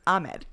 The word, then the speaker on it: Ahmed: Not Ahmed. I don't know how to say it like that. Ahmed. – Yeah, you said Ahmed it in the correct way.